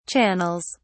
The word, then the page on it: channels: British English